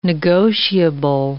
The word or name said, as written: negotiable